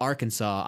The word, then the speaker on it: Arkansas: And it doesn't mean they're bad, but if you were to ask me one single fact about Arkansas, I would say it's not pronounced like Kansas.